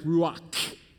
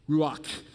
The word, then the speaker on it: ruach: So if you were Hebrew, it would really be like ruach, ruach. That's how you say it, all right?